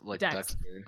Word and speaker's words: Dex: I go straight to the dance floor because I'm like, this is Dax Powers! Did I say that right? Dax? – Dex. – I'm like, Dex Powers!